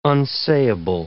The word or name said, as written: unsayable